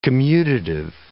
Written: commutative

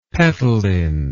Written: petaline

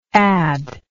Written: addedly

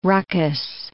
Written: ruckus

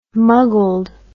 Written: muggled

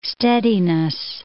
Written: steadiness

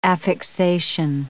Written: affixation

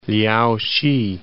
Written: Liaosi

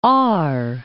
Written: Aar

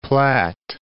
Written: Platte

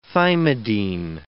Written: thymidine

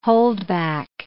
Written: holdback

